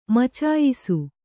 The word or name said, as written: ಮಚಾಯಿಸು